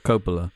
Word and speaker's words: Coppola: How do you say it? Coppola? Coppola? – I think it's Coppola. – Coppola. – Yeah.